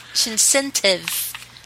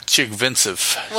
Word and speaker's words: Chigvintsev: Jamie Anderson, who was an Olympic snowboarder, is paired with Artem Chigvintsev. I know I said that wrong. – Chigvintsev. – Whatever. – Chigvintsev.